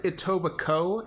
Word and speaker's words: Etobicoke: From Etobicoke, Ontario? – Yeah. You know what? I think it's pronounced Etobicoke, and somehow the K-E at the end of it is not pronounced. Don't fucking ask me why.